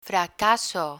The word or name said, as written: fracaso